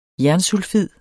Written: jernsulfid

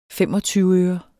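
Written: FEMOGTYVEØRER

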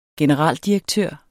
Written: generaldirektør

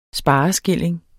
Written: spareskilling

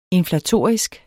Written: inflatorisk

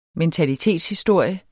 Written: mentalitetshistorie

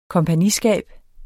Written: kompagniskab

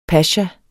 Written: pasha

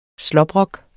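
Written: SLÅBROKKER

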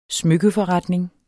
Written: smykkeforretning

